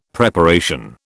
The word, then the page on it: Preparation: British English